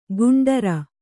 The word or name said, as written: ಗಂಡೀರ